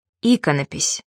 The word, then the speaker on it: иконопись: Правильно произносить «тефтель», «апостроф», «иконопись».